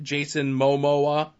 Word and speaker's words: Jason Momoa: Henry Cavill, Gal Gadot, Jason Momoa.